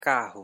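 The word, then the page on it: carro: Portuguese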